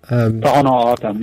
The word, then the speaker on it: Tohono O'odham: is it the Home Odom? Is it, am I saying that right? – Tohono O'odham, yeah.